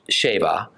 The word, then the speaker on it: Sheba: It's nearly certain that due to a copyist error, the spelling of Sheba, which is actually pronounced Sheba, ought to have been a seen and not a sheen.